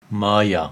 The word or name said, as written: Maya